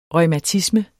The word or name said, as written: reumatisme